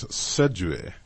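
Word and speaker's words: séduire: It's pronounced in French, Sidiri. Sidiri in English, S-E-D-U-I-R-E.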